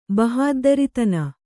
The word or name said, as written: ಬಹಾದ್ದರಿತನ